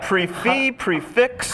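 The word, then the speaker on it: prix fixe: Pronounce that for me. – Yeah. – Is that prefix? – How do you pronounce that? – Come on. What should people say? – Prefix. – Prefix. – Prefix. Okay.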